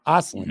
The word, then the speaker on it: Ostlund: And they took Noah Oslin. Oslin. Mm-hmm. I said that right. 2 for 2, baby.